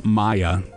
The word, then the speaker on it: Maja: Perry, Maja, Xander, Maya, the J is silent. Sorry, Maya.